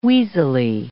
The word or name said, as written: wheezily